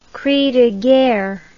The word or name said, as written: cri de guerre